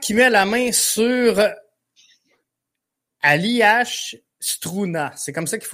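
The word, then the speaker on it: Aljaž: Là, c'est A-L-J-A-Z, mais ça se prononce vraiment Aliash, comme tu l'as dit c'est vraiment bien, par exemple.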